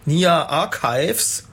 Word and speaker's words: Nia Archives: das war Vertical von Nia Archives. Habe ich das richtig ausgesprochen? – Hast du fein ausgesprochen. Richtig gut. Richtig gut.